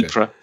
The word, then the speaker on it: Ypres: But Ypres seems to be the standard English pronunciation. Okay. Ypres,